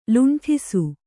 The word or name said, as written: ಲುಂಠಿಸು